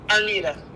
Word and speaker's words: Arnita: Arnita. – Can you spell it for me? – A-R-N-I-T-A.